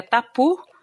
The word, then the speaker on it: tapu: A primeira, né, que eu, coloquei é, é, a palavra tabu, que para em alguns povos se pronuncia tapu, né?